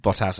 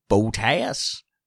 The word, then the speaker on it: Bottas: And Felix says they all have to drink as well, because you've incorrectly pronounced Bottas as Boltaeus.